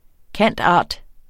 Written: cand.art.

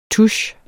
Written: touche

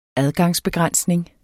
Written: adgangsbegrænsning

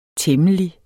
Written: temmelig

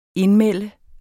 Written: INDMELDER

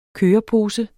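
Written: kørepose

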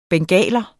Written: bengaler

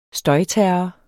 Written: støjterror

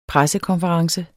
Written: pressekonference

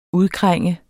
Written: udkrænge

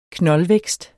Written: knoldvækst

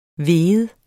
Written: vigende

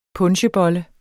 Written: punchebolle

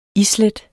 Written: islæt

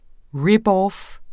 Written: ripoff

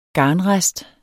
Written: garnrest

